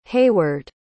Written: heyward

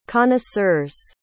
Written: connoisseur's

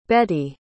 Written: Beti

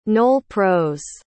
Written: nol-pros

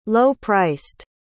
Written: low-priced

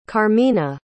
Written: karmina

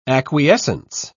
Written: acquiescence

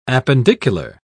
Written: appendicular

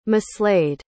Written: mislaid

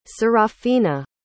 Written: Serafina

